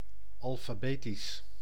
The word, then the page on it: abécédaire: France; /a.be.se.dɛʁ/